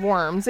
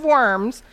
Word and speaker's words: Worms: At the Diet of Worms, and that's spelled D-I-E-T, and that is not the Diet of Worms. It is the Diet of Worms.